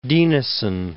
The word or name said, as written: Dinesen